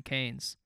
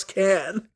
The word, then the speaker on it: Cannes: Cupolas, of the Carlton Hotel, built in 1912 in Cannes, are said to have been modeled.